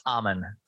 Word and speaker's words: Ammann: Yeah, it's Ammann.